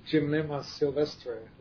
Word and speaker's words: Gymnema sylvestre: But no matter what she tried, fenugreek, Gymnema sylvestre, alpha... – Oh, that's Gymnema sylvestre, yes. Yeah, you're always butchering these Latin names.